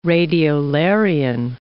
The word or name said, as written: radiolarian